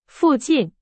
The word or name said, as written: 附近